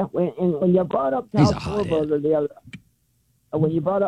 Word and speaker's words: Schwarber: Schwarber.